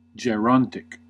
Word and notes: gerontic: American English

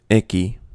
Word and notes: icky: American English

